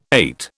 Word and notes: eight: British English

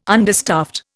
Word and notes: understaffed: Indian

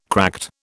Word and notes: cracked: British English

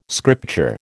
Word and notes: scripture: American English

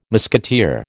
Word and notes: musketeer: American English

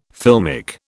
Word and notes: filmic: British English

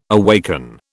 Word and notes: awaken: British English

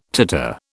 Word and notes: titter: British English

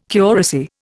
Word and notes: curacy: Indian

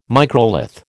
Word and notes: microlith: American English

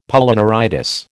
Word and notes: Polyneuritis: American English

